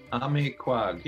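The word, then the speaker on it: Amikwag: Emmy Quag, is that pronounced? That's the name of the new record. Is that pronounced incorrectly or correctly? – Emmy Quag, yeah, that's about Emmy right. Quag.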